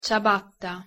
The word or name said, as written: ciabatta